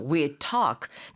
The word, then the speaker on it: we'd talk: 근데 we'd talk 같이 발음할 경우에는 we'd 하고 하는 그 d 하고 talk의 t 하고 발음점이 같거든요. 입안에서 이제 그 혀 끝이 붙는 지점이. 그래서 그냥 we'd talk. 거의 뭐 we'd talk 이렇게 발음되지 않고 we'd talk 하고 한 번에 끝내게 됩니다.